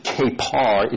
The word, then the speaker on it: kapar: The first is kapar. It's K-A, a long A, P-A-R. And it means to cover.